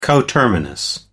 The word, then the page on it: coterminous: American English